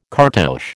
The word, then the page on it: cartouche: American English